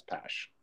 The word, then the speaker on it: Pash: Is it Pash? It's Pash? – Pash. Or, okay. Yeah, it's Pash.